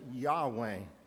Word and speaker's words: Yahweh: We call him Jehovah. They pronounced it Yahweh.